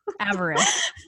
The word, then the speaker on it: avarice: I can never say this word correctly. – Avarice. – Yeah. I always want to do a long I.